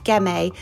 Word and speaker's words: Ghemme: The Red is a 2011 Gemmae that's spelled G-H-E-M-M-E,